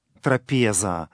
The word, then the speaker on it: трапеза: Слово «трапеза» в церковнославянском языке именно такое ударение.